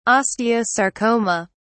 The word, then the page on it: osteosarcoma: British English